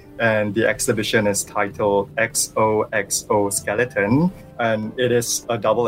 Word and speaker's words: XOXO Skeleton: And when you say it out loud, it also says exoskeleton.